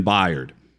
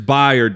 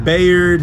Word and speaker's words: Byard: Here is Byard describing how many times he notices people getting his name wrong. – I've heard Bayard, like I said, Bynard. I've heard Bernard. Yeah, I just heard a lot.